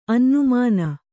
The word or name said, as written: ಅನ್ನುಮಾನ